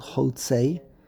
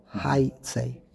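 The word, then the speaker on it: haitzei (היצא): And that's why Rashi made a big deal that take note in the word haitzei, it's spelled hotzei, but it's pronounced haitzei.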